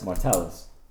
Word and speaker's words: Martellus: What is it, Martellus? – Martellus. – Oh, Martellus.